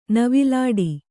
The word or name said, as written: ನವಿಲಾಟ